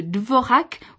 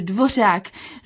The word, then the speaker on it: Dvořák: Ce matin encore, quelqu'un a parlé de Dvorak au lieu de Dvořák.